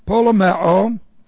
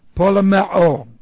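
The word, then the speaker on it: polemeo (πολεμέω): Okay, let's see, polemeo, and what we have here, this is P-O-L-E-M-E long O, polemeo.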